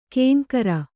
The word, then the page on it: ಕೇಂಕರ: Kannada